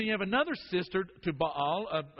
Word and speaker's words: Baal: We usually say Baal, but probably better pronounced Baal.